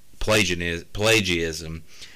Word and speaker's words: Pelagianism: And Pelagianism.